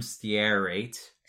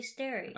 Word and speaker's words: stearate: And the same thing is for Smarties, except instead of starch, they're using calcium stearate. – Stearate. Just say stearate. – Oh, stearate. Derp.